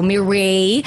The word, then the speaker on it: Mireille: It's not Mireille, it's Mireille. – Mireille. – Just Mireille. Very French pronunciation. So Mireille.